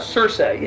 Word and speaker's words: Saoirse: Saoirse. Yeah. Like Cersei. Think of Cersei.